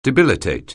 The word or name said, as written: debilitate